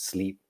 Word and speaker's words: Sliiip: It's spelled S L triple I P, but pronounced sleep.